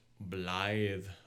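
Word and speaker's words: Blaidd: All right, so we were talking about Blythe, mm-hmm. the pupper. So, how do you pronounce that? Is it th at the end? – Yes, yes.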